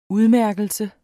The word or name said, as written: udmærkelse